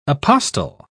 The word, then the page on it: apostil: British English